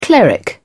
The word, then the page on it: cleric: British English